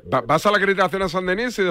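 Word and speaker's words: Saint-Denis: San Denis. No, como suena, San Denis.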